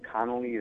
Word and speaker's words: Connolly: That's simply my name, and Connolly is spelled C-O-N-N-O-L-L-Y. So, again, it's two N's, two O's, two L's.